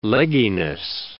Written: legginess

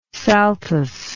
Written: saltus

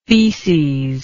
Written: faeces

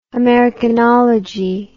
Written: americanology

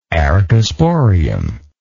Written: archesporium

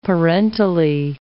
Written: parentally

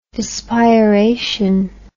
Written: dispiration

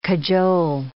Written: cajole

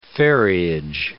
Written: ferriage

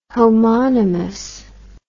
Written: homonomous

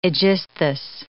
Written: Aegisthus

